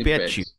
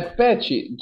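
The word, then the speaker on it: Patty: Aí se eu falar é do jeito que você colocou aqui, eu ia errar demais, hein, Não, é pet, tudo bem. É porque no inglês tem gente que pronuncia assim, mas no Brasil é, é. Sandy Pet. Pet, isso, isso.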